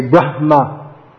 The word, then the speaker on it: Brahma: So this is when we see HM again, strict Sanskrit speeches, they'll strongly aspirate that age, they'll say Brahma. Although in modern Indian English, it doesn't sound much at all. But if we do pronounce it more, it's better. Brahma, Lord Brahma, not Lord Brahma.